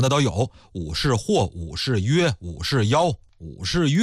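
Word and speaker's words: 武士彟: 武则天的父亲叫武士约，有人考证啊，说他父亲武士约的这个约字呢，应该念成是妖，跟那个大约的约有点相近。比方说咱们买东西了，这秤给的够不够分量啊，你再重新给我腰一下，或者说你上秤腰腰，你多少斤了。有专家学者呀考证出来，说这个武则天的父亲呢，应该是读作武士妖，但是我们就用约定俗成的吧。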